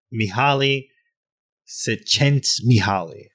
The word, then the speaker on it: Mihaly Csikszentmihalyi: Mihaly Csikszentmihalyi.